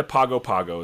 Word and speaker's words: Pago Pago: The G is kind of like weird because I live in Fongo or something, but it's all like G. I can't even hardly say it myself. But yeah, Pago Pago.